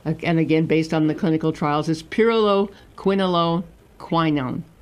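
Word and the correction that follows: pyrroloquinoline quinone: Did I say it correctly? – Pyriloquinoline quinone.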